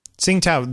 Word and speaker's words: Tsingtao: And there's one of a girl drinking some, how do you pronounce that? Tsingtao? – Tsingtao, the beer.